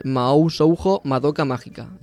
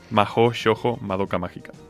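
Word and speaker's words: Mahō Shōjo Madoka Magica: Hoy os traemos un anime que es Mao Shoujo Madoka Mágica. – Muy bien, muy buena pronunciación. – A ver, yo no entiendo de japonés. – Mao Shoujo Madoka Mágica.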